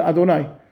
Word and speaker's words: Adonai: We pronounce it Aleph, Dalet, Nun, Yud, Adonai.